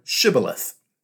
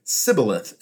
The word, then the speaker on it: Shibboleth: If the answer was no, he would be asked to pronounce the word Shibboleth. Apparently the Ephraimites had a bit of a speech impediment and would say Sibboleth instead.